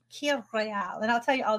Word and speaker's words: Kir Royale: Called Kir Royale. It's spelled K-I-R Royale.